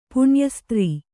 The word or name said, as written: ಪುಣ್ಯಸ್ತ್ರೀ